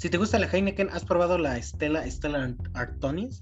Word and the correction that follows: Artois: ¿O cómo se pronuncia? – Artois, algo así.